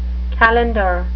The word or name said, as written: calender